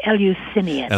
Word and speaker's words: Eleusinian: I always mispronounce that. – It's hard. I would say Eleusinian. – Eleusinian mysteries.